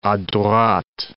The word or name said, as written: a droite